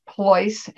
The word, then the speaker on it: Pleuss: It's spelled P as in Peter, L-E-U-S-S, Ploise, as in choice voice or Rolls Royce.